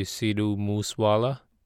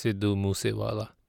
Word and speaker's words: Sidhu Moosewala: In English, the pronunciation might be Sidhu Moosewala. But in my accent, it's Sidhu Moosewala.